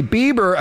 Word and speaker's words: Bieber: Anyway, Bieber apparently, if I'm saying his name correctly... – It is Bieber.